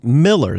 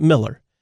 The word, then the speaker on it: Mueller: Spelled M-U-E-L-L-E-R. He pronounced it Miller.